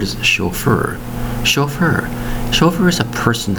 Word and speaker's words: chauffeur: It's called a chauffeur. Spelled S-C-H-A-U-F-F-E-U-R. Two syllables for the word chauffeur. Spelled C-H-A-U-F-F-E-U-R.